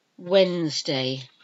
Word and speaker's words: Wednesday: Wednesday. Wednesday. It's not Wednesday, although that's how it's spelled. It's Wednesday. Wednesday.